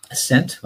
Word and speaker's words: Ascent: Ascent.